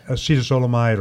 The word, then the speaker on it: acetazolamide: Acetazolamide or Diamox.